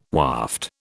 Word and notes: waft: British English

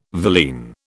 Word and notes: villein: British English

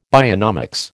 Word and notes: bionomics: American English

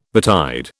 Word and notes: betide: British English

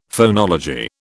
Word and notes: phonology: British English

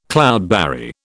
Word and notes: cloudberry: British English

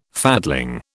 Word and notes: fatling: British English

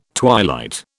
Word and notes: twilight: British English

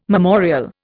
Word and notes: memorial: Indian

